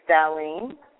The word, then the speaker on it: Darlene: My name is Darlene. – Hi, Darlene. Could you spell that, please? – D-A-R-L-E-N-E.